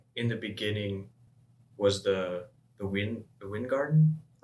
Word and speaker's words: Weingarten: I could be wrong, and I think it might actually be a German name just because of the way that it's spelled. Because it's, I think it's WeinGarten. – Mm-hmm. – And so when I first heard it, I thought people were saying wine garden, right? – Yeah.